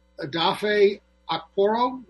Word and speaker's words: Adafe Okporo: Next week, our guest is going to be gay Nigerian refugee Adafe Okporo. Is that how you say his name? – Yes.